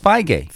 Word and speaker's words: Feige: Now, it's not pronounced right. It's pronounced Feige.